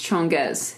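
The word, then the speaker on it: Changez: Spelled changes. – It's spelled like changes with a Z. – Mm-hmm. – It's pronounced Chongez.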